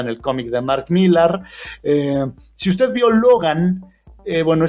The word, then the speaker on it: Millar: Que es un escritor conocido como Mark Miller o Millar. De hecho, hay quien dice que se dice Miller, aunque se escribe Millar, y usted pronúncielo como quiera, le van a entender.